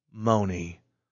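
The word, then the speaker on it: Mony: Moni. Moni, Moni. – Moni. – Moni, Moni. And then rhymes with pony.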